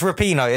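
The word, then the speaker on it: Rapinoe: Raponi. Raponi. I don't know how to pronounce it. Are you giving me points? – I'll give you a point for that. Yeah. Yeah, it's Rapino. It's pronounced Rapino.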